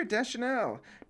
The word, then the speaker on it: Deschanel: And it was directed by Caleb Deschanel. And I guess I'll say it right here, Deschanel.